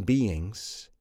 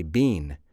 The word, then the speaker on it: being: some people don't properly pronounce the word being. When I say some people, I mean some native English speakers. They say bean. It has two syllables, being. Just like the gerund of the verb to be, being.